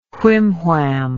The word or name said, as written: whimwham